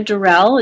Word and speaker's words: Dorell: And I'm under my name, which is Diana Durrell. It's spelled Diana, D-I-A-N-A, and then D-O-R-E-L-L.